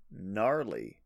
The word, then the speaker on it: gnarly: It's pretty gnarly. There's a silent G there.